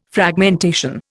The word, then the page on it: Fragmentation: Indian